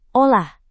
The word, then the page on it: olah: Indonesian